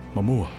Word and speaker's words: Momoa: Momoa. – M-O-M-O-A.